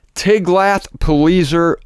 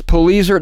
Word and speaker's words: Tiglath-Pileser: Tiglath, I think I can guarantee that. T-I-G-L-A-T-H. How else do you pronounce that? Tiglath. But it's the Pileser. It could be Pilesar. P-I-L-E-S-A-R. Sounds like a beer. Some kind of beer. Oh, that's Pilsner. Oh, sorry. Anyway, I think it's Pileser.